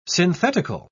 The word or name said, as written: synthetical